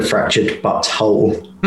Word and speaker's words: Fractured But Whole: I've played both of them, the Stick of Truth and the Fractured But Whole.